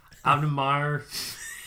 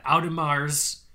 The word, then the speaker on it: Audemars: It's Audemars. We'll do it slowly. Audemars. You have to emphasize the S at the end. They like that. Audemars.